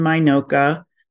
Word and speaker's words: MINOCA: In the English language, we say INOCA and MINOCA.